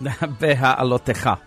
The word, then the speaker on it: Beha'alotecha: Beha'alotecha.